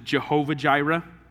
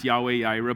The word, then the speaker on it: Yahweh Yireh: If you grew up in church, you probably heard this pronounced as Jehovah Jireh. Does that sound familiar? A better pronunciation of that is Yahweh Yireh, but for some people, what you learn first is always what you like best.